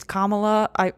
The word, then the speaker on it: Kamala: should have more mandates and more of a lockdown or hasn't handled it well, then probably you might agree more with what Kamala was saying. So, by the way, it's Kamala.